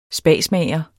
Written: spasmager

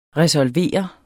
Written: RESOLVERES

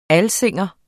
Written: alsing

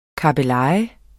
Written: kabbeleje

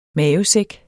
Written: mavesæk

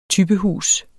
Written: typehus

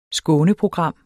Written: skåneprogram

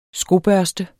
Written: skobørste

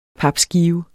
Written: papskive

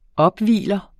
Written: opvigler

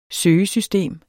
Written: søgesystem

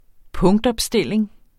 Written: punktopstilling

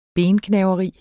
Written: bengnaveri

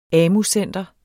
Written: AMU-center

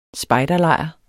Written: spejderlejr

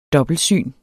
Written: dobbeltsyn

